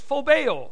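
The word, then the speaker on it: Phobeo (φοβέω): The first word is phobeo. Phobeo, this is how you pronounce it.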